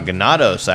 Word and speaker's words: Ganados: Ganados, actually, I think is how it's pronounced. – That's true.